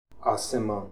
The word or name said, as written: Asiman